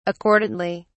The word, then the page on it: accordantly: British English